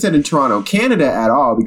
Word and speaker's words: Toronto: The fact that it's set in Toronto, or Toronto, as I think people in Toronto pronounce it, Toronto. You're not supposed to pronounce that second T.